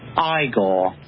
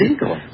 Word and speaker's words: Igor: You must be Igor. – No, it's pronounced Igor. – But they told me it was Igor. – Well, they were wrong then, weren't they? – The Frankenstein.